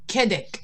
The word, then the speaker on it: Kedic: So the name of your company is pronounced for me, Kedic? Kedic? – Kedic. It's K-E-D-I-C.